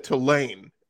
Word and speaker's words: Tulane: In New Orleans, we put the emphasis on the first part, so it's Tulane. – Hmm. – You said Tulane, and that's very northern of you.